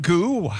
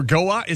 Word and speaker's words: Goux: We're spelling goo, G-O-U-X. – Is that how you pronounce it? – I would think goo or go-ux. It's not go-ux, I can tell you that.